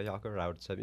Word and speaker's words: Jaco Raudisep: Yeah, Jaco Raudisep. You got to roll the R. Technically, I can't say my own last name. I don't know how to roll my R.